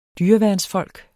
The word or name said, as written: dyreværnsfolk